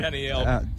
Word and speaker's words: Danielle Bradbury: So we did Daniel Bradbury. That was a fantastic interview. – Danielle. – I said Danielle. Danielle. – Sounds like Daniel. – Danielle. Danielle. – She pronounced it Danielle.